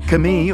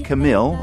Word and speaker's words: Camille: Camille, or Camille, as we pronounce it in English, is super well known in France.